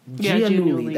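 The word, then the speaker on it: Giannulli: they basically, specifically for Lori Loughlin and Massimo Giuliani or Gianulli. – Gianulli, that's how you pronounce it.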